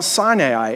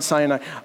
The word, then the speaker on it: Sinai: And he brings them to Mount Sinai. And yes, that is actually how you pronounce it. Read it. It's not Sinai.